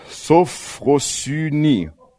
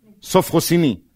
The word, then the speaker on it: Sophrosyne: Kommt von dem griechischen Wort Sophrosyne. Habe ich es richtig gesagt? Nee. Sophrosyne.